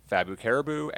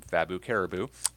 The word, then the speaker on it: FabuCaribu: My name is FabuCaribu, and also on Instagram under the same name, FabuCaribu. It is spelled F-A-B-U, fabulous, and Caribu is not spelled like you would think. It's spelled C-A-R-I-B-U, because I wanted the B-U at the end for both ends.